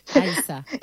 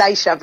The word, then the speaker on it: Aixa: ¿Pronuncié bien el nombre? ¿O Aisha? Sí. Aisha. Es Aisha, pero no te preocupes.